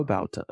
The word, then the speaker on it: Babauta: Leo Babauta.